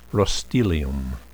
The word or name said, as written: roestelium